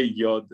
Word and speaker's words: yod: So I think it is indeed a yod, how did you say yod? Rhymes with odd, yes.